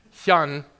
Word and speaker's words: Hyun: Hyun is the correct pronunciation, spelled the same way.